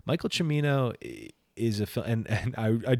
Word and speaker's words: Michael Cimino: I for sure up until this point was saying Michael Cimino on this podcast, because that's the way that my brain read that when I was younger, and I've never, for whatever reason, never been able to get that pronunciation out of my head. But it's Michael Cimino.